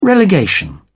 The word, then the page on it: relegation: British English